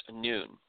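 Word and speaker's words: noon (ن): It is the 14th letter of the Arabic alphabet pronounced noon.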